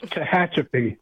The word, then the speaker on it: Tehachapi: And the town is much harder to pronounce than that. It's Tehachapi.